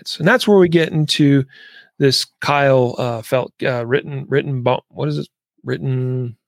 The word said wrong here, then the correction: Rittenhouse: I'm having a I'm not sure how to pronounce it. – Kyle Rittenhouse. Is that what it is? – Rittenhouse.